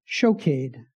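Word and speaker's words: shokade (שׁוֹקֵד): And the word for I am watching is shokade. It rhymes.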